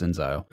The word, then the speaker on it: inXile: Like, instead of Exile, it's Inxile.